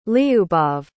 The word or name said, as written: liubov